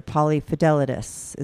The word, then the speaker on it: polyfidelitous: So what's polyfidelity or polyfidelitous? Is that how, am I saying it correctly? – Yeah, yeah, you are.